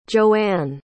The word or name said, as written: joeanne